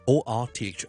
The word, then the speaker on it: orthopaedic: 骨科咧，我哋叫做 orthopedic 呢个相对容易嘅 orthopaedic author 其实听落去咧系同作家一样 author 一样发音，跟住 pedic orthopedic 咁呢个就系骨科。